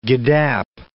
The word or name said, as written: giddap